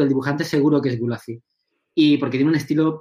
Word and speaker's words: Gulacy: Entonces, bueno, pues Gulacy, que se escribe así, Gulacy, G-U-L-A-C-Y, él dibujaba seguro esa etapa de Shang-Chi.